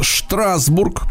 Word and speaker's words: Штрасбург: Но на самом деле это давний немецкий город Штрасбург, потому что СТ, вот это сочетание букв, оно читается как э, Эшт, Шт, понимаете, uh-huh. да, в немецком языке.